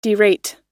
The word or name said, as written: derate